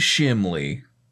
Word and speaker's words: Shimley: I'm Wade Shimley.